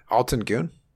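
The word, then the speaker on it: Altın Gün: Uh, uh God, I can't, Altin Gün? Is that how you pronounce yeah, it? Yeah, yeah, yeah.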